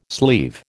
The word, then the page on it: sleeve: American English